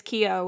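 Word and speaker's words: Keough: Yo, my last name is Keogh, which is Irish, and it's K-E-O-U-G-H.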